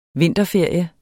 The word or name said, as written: vinterferie